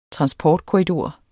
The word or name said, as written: transportkorridor